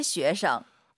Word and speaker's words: 学生: 저 학생들은 나시에 쉐이성. – 학생 발음하실 때 쉐이성 이성경성. 아하 잘하셔야 돼요. 학생 발음 다시 들어볼게요.